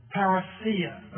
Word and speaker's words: Parousia: It's the Greek word Parousia. Let me spell that. P-A-R-O-U-S-I-A. Parousia. You may hear it pronounced parousia by the Englishman. But it's parousia, the coming of the Lord. It's a technical word for his grand second coming.